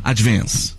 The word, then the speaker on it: advance: É um A puxando pro E, ó. Que viu? Olha a diferença, tá. Ó. Advance. Aí você falou advance, mas ó, é um E puxando, ó. É advance.